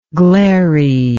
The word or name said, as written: glariness